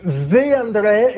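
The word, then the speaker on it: The André: Abbiamo un pezzo di Ve André, e non è un errore di pronuncia, perché se avete baticato YouTube degli ultimi anni lo potreste conoscere. Ve André, scritto come Ve, come l'articolo inglese, ecco,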